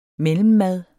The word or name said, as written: MELLEMMAD